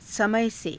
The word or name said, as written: samaya se